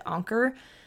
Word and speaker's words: Anker: My car charger in my car is an Anker car charger, and a lot of YouTubers have talked about them before, and I think some people pronounce it Anchor, um, but it's spelled A N K E R, and they're really great.